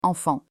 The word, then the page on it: enfant: French